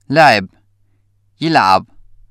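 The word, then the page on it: لعب (يلعب): Syrian Arabic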